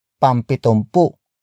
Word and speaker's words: pampitumpu: Pampitumpu.